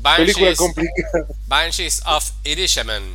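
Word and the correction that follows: Inisherin: Y no, Inishirin, Inishirin, sí.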